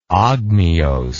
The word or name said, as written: ogmios